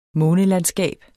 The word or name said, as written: månelandskab